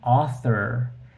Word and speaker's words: author: In English, the word author is pronounced like all, even though it's written like a A-U, author. This is really important for me because it's a very common spelling combination, the A and the U. And normally, when you hear it, you're going to pronounce it as all instead of au.